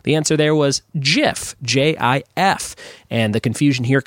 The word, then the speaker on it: GIF: So Jif is the answer there. Also, Jif is how you're supposed to pronounce GIF.